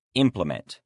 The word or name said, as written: implement